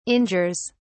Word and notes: injures: British English

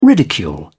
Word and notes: ridicule: British English

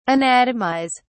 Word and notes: anatomise: British English

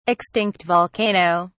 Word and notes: extinct volcano: British English

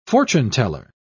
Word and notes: fortuneteller: British English

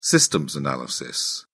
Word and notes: systems analysis: British English

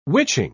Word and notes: witching: British English

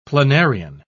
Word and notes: planarian: British English

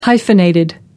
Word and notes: hyphenated: American English

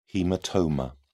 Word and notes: haematoma: British English

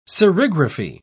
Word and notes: serigraphy: British English

